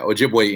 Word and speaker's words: Ojibwe: So, you are a tribal citizen of the Red Lake Nation of Ojibwe Indians. Did I say that correctly? – Yeah, you did. Yeah, Ojibwe Indians.